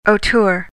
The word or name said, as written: auteur